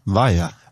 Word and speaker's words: Vaja: Ja, Vaja.